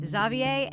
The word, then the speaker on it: Xavier: And a second note, while I pronounce Xavier's name Xavier, our guest Clay Jeter pronounces it Xavier, and I sometimes do as well.